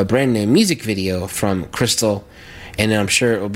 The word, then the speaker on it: Khrystal: Crystal is spelled K-H-R-Y-S-T-A-L.